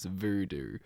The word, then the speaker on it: X88B88: And if you look at the name, you're like, Jim, how is that pronounced Voodoo? It's spelled X88B88. But if you imagine it as like a little horizontal line of symmetry, and it's like reflected in that, it's Voodoo, which I think is so clever and is just really matches how clever the production on that track is.